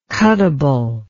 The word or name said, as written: cuttable